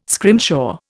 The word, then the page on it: scrimshaw: Indian